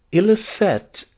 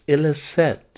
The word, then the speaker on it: Ilicet: The two books are, one, Ilicet, A Time to Begin Again. That is Ilicet, a time to begin again. Ilicet is spelled I-L-I-C-E-T. That's a Latin word, of course.